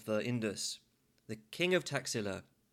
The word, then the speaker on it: Taxila: Taxila is actually pronounced Takshashila in Sanskrit, but I'm going to go with the modern pronunciation, Taxila.